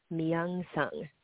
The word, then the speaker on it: Myung Sung: I didn't want to correct you before, but it's Myung. You do pronounce the M, but the Y, it sounds like Myung. Myung Sung.